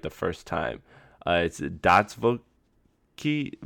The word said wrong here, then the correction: Dostoevsky: I, can't ever get it right the first time, it's man. Dostoevsky.